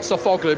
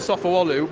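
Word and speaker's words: Sofuoğlu: I've actually been told by our Turkish Eurosport commentator that you don't pronounce G's in Turkish. So strictly speaking, it would be Kenan Sofuoglu, not Sofoglu, but Sofuoglu, which would also mean Toprak Razgatioglu.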